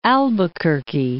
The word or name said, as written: Albuquerque